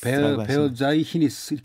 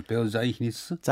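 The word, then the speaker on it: Verzeichnis: 베어자이히니스 이렇게 발음이 되는 베어자이히니스.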